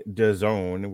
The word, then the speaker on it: DAZN: All right, so there was another fight here on DAZN, which rhymes with calzone.